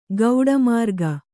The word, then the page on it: ಗೌಡಮಾರ್ಗ: gauḍa mārga